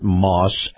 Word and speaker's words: Maus: is brought to you by our friends at Moss Acura. Moss is spelled M-A-U-S.